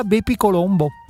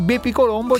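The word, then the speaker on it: BepiColombo: Era un matematico di Padova di qualche secolo fa, gli hanno intitolato proprio l'Agenzia Spaziale Europea questa sonda e quindi Bepi Colombo ci dirà qualcosa su Mercurio. – Per quello lo pronunci così, perché era di Padova.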